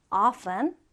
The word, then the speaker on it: often: 자, 두 가지 발음이 다 가능해요. 일단 첫 번째는 often. 자, t가 발음되지 않죠. often 또는 t를 발음해서 often. often. 자, 따라해 볼까요? often. often. 네, 둘다 가능합니다.